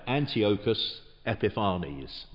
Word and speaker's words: Antiochus Epiphanes: a man called Antiochus Epiphanes. I'm sure there are various ways of pronouncing his name. That's the way I've always pronounced it. It seems right. Antiochus Epiphanes.